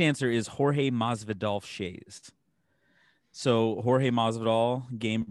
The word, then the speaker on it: Jorge Masvidal: It's Jorge Masvidal.